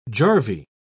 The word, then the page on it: jarvey: British English